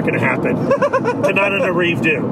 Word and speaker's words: Tananarive Due: Means Coleman, Ashley Blackwell, and Tananarive Due. No, I messed up the pronunciation, Billy. – Tananarive Due.